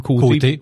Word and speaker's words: Côté: C'est O-T-E. Mais pourquoi ça se... Bon, on prononce quand même côté.